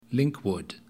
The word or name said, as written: Linkwood